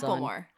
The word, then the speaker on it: Macklemore: It's Macklemore, not Macklemore. – Macklemore. Macklemore. – Ulmore, not Lumore. – Macklemore. – Like mackerel, right. Like a mackerel, like the fish.